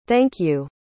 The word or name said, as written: thank-you